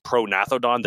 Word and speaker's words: Prognathodon: It probably is pronathodon. The G is silent. – Yeah. – But it's because like compsognathus is the same sort of thing.